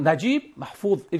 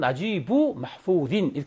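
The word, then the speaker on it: 나지브 마후딘 (نجيب محفوظ): 그냥 나지브 마후드 이렇게 발음하는데 표준말로는 나지브 마후딘 이렇게 해야 됩니다.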